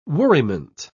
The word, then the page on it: worriment: British English